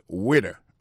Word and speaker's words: waiter: Alors moi, je prononce le « waiter ». Waiter, ça veut dire accent tombé sous le premier syllabe « waiter », W-A-I-T-E-R. Moi, c'est un garçon.